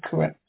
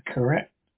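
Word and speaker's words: krait: Tonight we escape to India, the story of a man trapped in his bed by corrupt of those deadly snake, poisonous snake in the world, as Roald Dahl tells it in his terrifying story, Poison.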